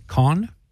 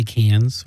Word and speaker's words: Cannes: Cannes. I mean, that's the proper way of pronouncing it. Okay. I guess some people say Cannes.